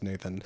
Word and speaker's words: Nathan: Nathan, maybe? Looks like it's pronounced Nathan.